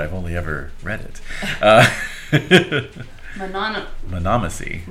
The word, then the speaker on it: monomacy: And it's called monomacy. – Spell that. – M-O-N-O-M-A-C-Y.